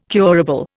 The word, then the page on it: curable: Indian